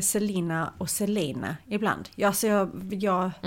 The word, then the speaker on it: Celaena: Men Selena, det stavas ju C-E-L-A-E-N-A.